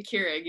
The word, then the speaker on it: Keurig: A Keurig, yeah.